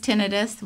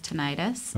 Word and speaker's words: tinnitus: Depends on where you went to school. – I think. The East Coast says tinnitus. West Coast says tinnitus.